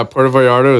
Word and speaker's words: Puerto Vallarta: Puerto Vallarta. Is that how they say it over there? – Yeah, Puerto Vallarta is pretty fucking sweet.